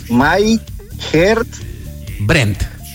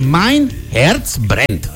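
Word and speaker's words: Mein Herz brennt: Mein Herz Brent. – Ahí está. Entonces, Mein Herz Brent. – My Herz Brent.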